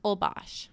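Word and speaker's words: Holbox: And that's spelled H-O-L-B-O-X. I got so many DMs asking how we pronounce it. So it's Olbox.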